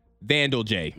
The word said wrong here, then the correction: Vandelay: No. Art Vandelay. – Vandelay. – Art Vandelay. – Oh, yes, Vandelay, yeah.